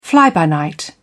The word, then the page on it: fly-by-night: British English